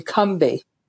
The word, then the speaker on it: Combahee: And again, a little fun fact is that we've been pronouncing it incorrectly all these decades. It's actually Cumbie, as if it's two syllables with no A in the middle.